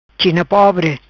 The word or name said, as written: China pobre